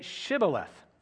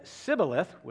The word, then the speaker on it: shibboleth: If he replied, "No," they said, "All right, say Shibboleth." If he said Sibboleth, with a different pronunciation because he could not pronounce the word correctly, they seized him and killed him at the fords of the Jordan.